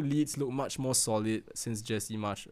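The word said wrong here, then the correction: Jesse Marsch: Am I saying that right? Or is it Marsh? – I think it's Jesse Marsh, right? – Mm-hmm.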